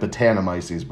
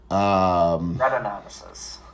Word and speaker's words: Brettanomyces: Long story short, they used Brettanomyces. Is that how you pronounce it? – I believe it's like Brettanomyces, but Brettan. – Brettanomyces.